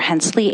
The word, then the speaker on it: Hensley: Mr. Chair, members of the committee, for the record, my name is Jennifer Hensley, H-E-N-S as in Sam, L-E-Y, Jennifer is spelled traditionally.